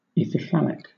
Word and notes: ithyphallic: British English

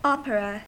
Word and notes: opera: American English

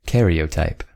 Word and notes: karyotype: American English

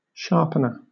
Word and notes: sharpener: British English

